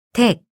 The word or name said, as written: 댁